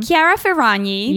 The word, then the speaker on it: Chiara Ferragni: Chiara Ferragni.